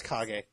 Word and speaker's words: Kage: I think it's Kage. – Kage? K-A-G-E.